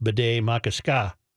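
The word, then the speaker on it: Bde Maka Ska: The board voted 4-3 to change the name to Bidet Makaska, pronounced Bidet Makaska.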